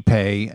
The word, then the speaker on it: Ipe: Yeah, and you mentioned ePay. That's spelled I-P-E. It's pronounced I-P-E. ePay, and yes.